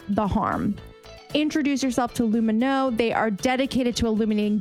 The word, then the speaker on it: Lumineux: Lumino, spelled L-U-M-I-N-E-U-X, is the first oral care line designed to strengthen your oral microbiome, saying no to lots of ingredients that can harm it. That's why they pronounce their name Lumino, because it has no harsh bleaches, no artificial dyes, no alcohol.